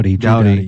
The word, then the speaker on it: Doughty: My question, now that they won it, and I know how competitive the players are, I can't say his name, Drew Doughty? – Doughty, yeah.